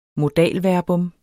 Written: modalverbum

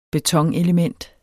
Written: betonelement

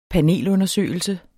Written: panelundersøgelse

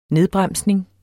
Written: nedbremsning

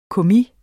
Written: kommis

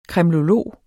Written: kremlolog

